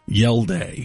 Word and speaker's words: Hjalte: The Cardinals center, speaking of offensive line, Yelde Froholt. – Sure. – From Denmark. Has that weird spelling of his name. His first name is spelled Hjalte. Yelde.